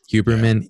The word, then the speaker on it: Huberman: And Andrew Huberman, is that how it's said? I spelled out. Huberman, yeah, Huberman.